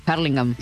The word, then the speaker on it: Hurlingham: Es que en realidad la pronunciación correcta es la palabra en inglés. – ¿Cómo sería? – Hurlingham.